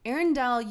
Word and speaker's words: Arendelle: I'm probably pronouncing one of these incorrectly. – Arendelle, you are pronouncing correctly. – Okay. The actual port is A-R-E-N-D-A-L.